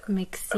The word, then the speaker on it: Mixinuk: Is Mixinuk, is that correct? – Yeah, I mean, it's more like Sinok, like a long E.